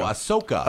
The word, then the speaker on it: Ahsoka: So getting into this, the audience welcomed teasers from Ahsoka. How do you say it? The Rosario Dawson TV show. – Oh, Ahsoka.